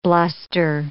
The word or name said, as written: bluster